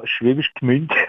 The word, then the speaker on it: Schwäbisch Gmünd: aus Schwäbisch Gmünd rufst du uns an. – Ja, Schwäbisch Gmünd.